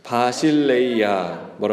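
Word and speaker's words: 바실레이아 (βασιλεία): 바실레이아.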